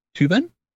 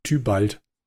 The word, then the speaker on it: Tybalt: Tübern? Nein. Tybalt.